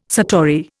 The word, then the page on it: satori: Indian